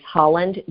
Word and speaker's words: Holland: It is Holland, just like the country, H-O-L-L-A-N-D. And my last name is Haas, H-A-I-I-S, like Sam.